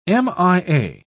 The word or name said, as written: mia